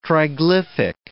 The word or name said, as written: triglyphic